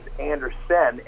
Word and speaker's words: Andersen: My first name is J-O-N, which is uncommon, and my last name is Anderson, A-N-D-E-R-S-E-N, which is also uncommon.